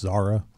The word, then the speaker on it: Zara: uh, is is pronounced Zara.